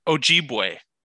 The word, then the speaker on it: Ojibwe: Ojibwe.